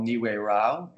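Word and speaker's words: Nihue Rao: Niue, so the place that Ricardo owns is called Niue Rau.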